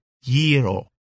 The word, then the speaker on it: gyro: It pronounced gyro, you see? GY is ye, not ja. Yiro. That's what you say.